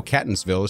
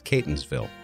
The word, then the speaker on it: Catonsville: Just so you know, Catonsville is pronounced with a long A. It's Catonsville.